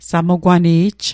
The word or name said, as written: samqwani'j